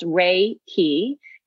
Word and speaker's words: Reiki: It's broken down to two syllables, Reiki, and Rei, I want you to think of Rei like the sun.